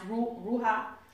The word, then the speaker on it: ruah: pronounced Ruha, R-U-A-H.